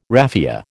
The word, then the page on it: raffia: American English